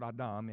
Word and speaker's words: Adam: But this word, Adam, is the word Adam, is how you say it in the original language.